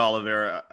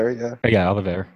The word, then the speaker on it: Oliveira: I thought Oliveira, is that how you say his name? Oliveira? – I just say Oliveira.